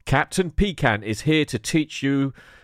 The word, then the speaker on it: Pikant: This is a little channel called Captain Picant, and that is spelled Captain, as you would expect, and Picant is spelled P-I-K-A-N-T.